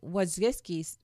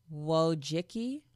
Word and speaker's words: Wojcicki: And Wojcicki, I'm totally butchering her name. It's W-O-J-C-I-C-K-I. Wojcicki? Let's call it Wojcicki.